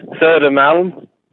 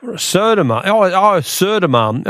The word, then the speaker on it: Södermalm: Södermalm. I can't remember the other islands you were talking about. – Södermalm.